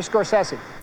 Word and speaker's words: Scorsese: Now, before we get into why he's such an important filmmaker, I thought, Scott, we would end the debate once and for all on how to pronounce his name. Jute, I have a clip of Scorsese pronouncing it for you guys. Can you roll it? – Hey, it's Marty Scorsese.